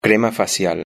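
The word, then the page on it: crema facial: Spanish (Spain)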